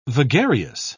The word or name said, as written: vagarious